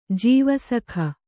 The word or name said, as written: ಜೀವಸಖ